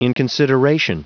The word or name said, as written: inconsideration